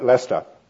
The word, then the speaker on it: Leicester: It's spelled Leicester, but we have funny ways of pronouncing things, so we call it Leicester.